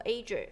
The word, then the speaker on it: Azure: 微软这个它可以念作 Azure， Azure 哈，好像这个这三大是目前你说最大的这个公有云。